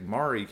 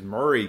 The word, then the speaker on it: Maury: I was I was about to say like Maury County. It's like no no no they pronounce it a different way. It's like it was Murray County. Um, for some reason the A is not pronounced, but uh, in and that's the way it was pronounced in Tennessee.